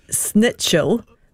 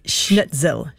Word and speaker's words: schnitzel: And this one, my friends say snitchel when it should be schnitzel. – Schnitzel. – Yes. – Schnitzel.